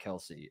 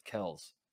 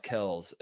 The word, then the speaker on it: Kelce: And I say that because a month ago, you might remember, he just out of nowhere drops the bomb that like his name is not Kelce, it's Kels. – Yeah. – It's pronounced Kels, and so there's like a transformation.